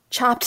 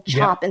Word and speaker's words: chopped: And I don't know why chopped salad, why people drop the – it's the same syllable. Like, you're not saving time by saying chop yeah. instead of chopped.